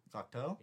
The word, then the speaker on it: Cocteau: How do you pronounce that? – That's Cocteau. – Cocteau? – It is Cocteau.